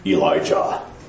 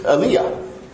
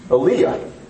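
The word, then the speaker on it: Aliyah (אֵלִיָּהוּ): So today we're going to look at a man that we commonly know as Elijah. Okay? That's how we pronounce it. It's wrong. That's not that you have to change your kid's name or anything, but the Hebrew is Aliyah. Isn't that weird? Aliyah, not Elijah.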